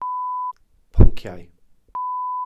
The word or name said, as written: ponciau